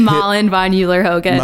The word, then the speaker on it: Malin von Euler-Hogan: Malin, Von, Euler-Hogan.